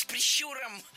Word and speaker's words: прищур: Очень часто я слышу это слово с ударением на первом слоге, но это ошибка. Наша гостья произнесла слово «прищур» абсолютно верно. Прищур, прищура, прищуром. Всегда ударение в этом слове ставим на второй слог.